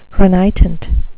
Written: renitent

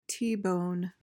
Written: t-bone

